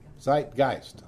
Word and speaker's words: Zeitgeist: Is that pronounced right? – Zeitgeist. – Zeitgeist. I'll get it right one day.